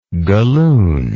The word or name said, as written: gallooned